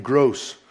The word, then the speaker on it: Gros: Gross? Everyone's teasing me, you know, kind of thing. So, but in French, they don't pronounce the S. It's Gros, I guess.